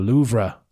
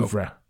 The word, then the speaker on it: Louvre: The Louvre.